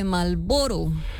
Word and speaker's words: Marlboro: Más ignorada que la R de Marlboro. – Oye, sí, es que una R nunca suena. – No se pronuncia.